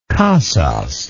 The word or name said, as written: casas